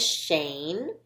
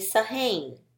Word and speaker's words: Shane: His name is Shane, and he has that SH digraph sound at the beginning. When you put that SH sound together, you get shh. Otherwise, his name would be Sahane. That just sounds weird. That's just not right.